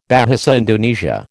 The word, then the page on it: Bahasa Indonesia: American English